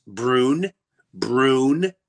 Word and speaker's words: Breuniverse: I went, "Just think of brewer and universe. Breuniverse. – Sound it out.